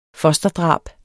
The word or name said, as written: fosterdrab